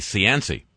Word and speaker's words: Cianci: Ex-Mayor Buddy Cianci.